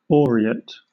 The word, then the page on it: aureate: British English